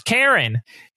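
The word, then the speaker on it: Charon: And Karen is the ferryman to the underworld, spelled either C-H or K-H. I spelled it K-H so I can remember that it's pronounced Karen.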